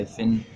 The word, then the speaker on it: Fionn: I'm going to say Finn. – Oh. – It's spelled F-I-O-N-N Whitehead.